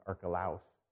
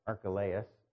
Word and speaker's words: Archelaus: Sometimes I hear it pronounced Archelaus, but Archelaus, Herod Antipas.